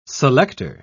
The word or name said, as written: selector